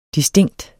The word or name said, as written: distinkt